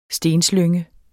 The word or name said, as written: stenslynge